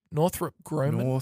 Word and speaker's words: Northrop Grumman: How do you say that? Northrop Grumman? – Northrop Grumman.